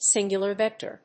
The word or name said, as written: singular vector